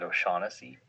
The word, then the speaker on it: O'Shaughnessy: Ranger describes when he patrolled a loop from Oshastna Dam in the, I think I'm pronouncing that wrong, Oshastnessy. Do you know that dam? – Is it O'Shaughnessy? – Yes, thank you.